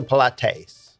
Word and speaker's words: Pilates: Pilates or Pilates? – Pilates.